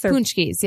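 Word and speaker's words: Pączki: Are they Poochkis? – Poochkis, yeah. – Oh, Poochkis. Is that how you pronounce it? – Yeah.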